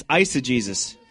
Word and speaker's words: eisegesis: It's eisegesis. Did I say it right? – Eisegesis. – I got it. I said it right.